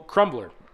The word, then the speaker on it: Krumblr: So, Crumbler. It's spelled K-R-U-M-B-L-R. – B-L-R.